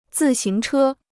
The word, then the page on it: 自行车: zì xíng chē